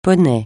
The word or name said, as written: poney